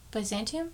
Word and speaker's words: Byzantium: Byzantium? – There we go.